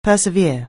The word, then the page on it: persevere: anglais